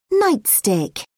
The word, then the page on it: nightstick: British English